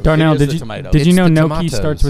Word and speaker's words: gnocchi: Gnocchi. – It is a food. – It's actually spelled with a G.